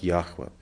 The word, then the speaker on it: Яхве: То есть только примерно где-то с 19 века библеисты восстановили, что сущий, тетраграмматон читался именно как Яхве, это только с 19 века.